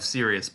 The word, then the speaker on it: Sirius: and we get the official pronunciation of Sirius Black because he's like quote literally the word Sirius.